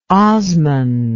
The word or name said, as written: Osman